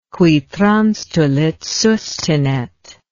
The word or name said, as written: qui transtulit sustinet